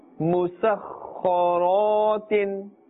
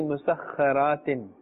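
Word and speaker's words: مسخرات: Kha is a musta'aniya letter, so it must be pronounced with a full mouth, a thick tone. Ra has a fatha above it, therefore this as well we are pronouncing with a full mouth. Do not say musakharatin.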